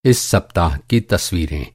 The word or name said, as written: सप्ताह